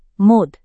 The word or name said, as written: mode